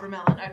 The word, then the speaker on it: Abramelin: We are here with Abramelin. Am I saying that correctly? – Yes, you are. – Abramelin.